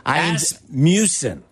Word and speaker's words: Asmussen: Muffin is a mispronunciation of one of the jockeys riding in tomorrow's Kentucky Derby. – That was amazing. – Asmussen. Asmussen? I think that's how you pronounce it. Asmussen.